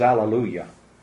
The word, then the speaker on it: alleluia: Why do you suppose Greek comes out always alleluia? – because the H in Greek is actually written as an apostrophe and isn't really pronounced, so they didn't pronounce it in English.